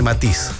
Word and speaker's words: Matisse: Se escribe Matisse, pero se dice Matiz.